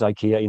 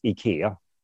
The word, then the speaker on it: IKEA: Well, in English, it's IKEA. In Swedish, it's IKEA.